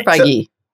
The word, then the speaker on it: Feige: Is it Feige or Feige? So, Feige. I believe it's Feige. I believe you Feige. Say the E.